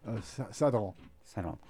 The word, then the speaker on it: Sadrant: Sadron.